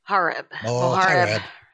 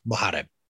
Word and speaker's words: Mohareb: Mohareb. Yes. – Okay. Yes. Say it fast. Say your name. – Mohareb.